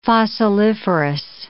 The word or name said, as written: fossiliferous